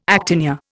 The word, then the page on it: actinia: Indian